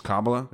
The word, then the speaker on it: Kabbalah: It's just Kabbalah? Yeah. Kabbalah? Sounds like Kamala, right?